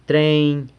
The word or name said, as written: treiny